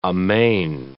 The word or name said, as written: amain